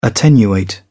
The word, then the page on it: attenuate: British English